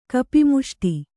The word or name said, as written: ಕಪಿಮುಷ್ಟಿ